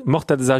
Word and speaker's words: Mortaza: Bonjour Mortaza. Je ne sais pas si je prononce bien votre prénom. – Si, si, c'est Mortaza, c'est M-O-R-T-A-Z-A.